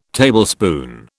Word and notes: Tablespoon: British English

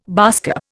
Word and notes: baasskap: Indian

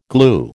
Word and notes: glue: American English

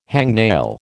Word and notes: hangnail: American English

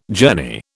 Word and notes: journey: British English

